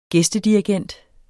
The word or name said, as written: gæstedirigent